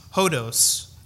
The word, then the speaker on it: hodos: which in the Greek is pronounced hodos,